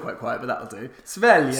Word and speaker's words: Sverige: It's not pronounced that. It's pronounced like this. – Sverige.